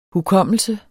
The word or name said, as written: hukommelse